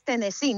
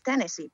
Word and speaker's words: Tennessee: Y Tennessee es Tennessee, no es Tennessee, por cierto.